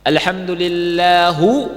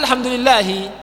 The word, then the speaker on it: Alhamdulillahi: Seperti kalau ada orang baca gini, Alhamdulillahu Rabbil Alamin. Salah enggak? Salah. Alhamdulillahu Rabbil Alamin. Orang awam, loh kok dia bacanya Alhamdulillahu? Kan gitu. Kan mestinya Alhamdulillahi.